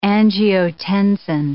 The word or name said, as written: angiotensin